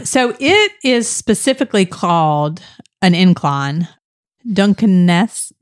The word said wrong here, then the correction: Duquesne: incline? Am I saying that correctly? – Duquesne. – Oh, sorry. Really terrible. Duquesne. Okay.